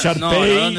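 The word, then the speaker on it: shar pei: Es Sharpay. Sharpay. – Es Sharpay con lo que se escribe.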